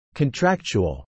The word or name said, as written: contractual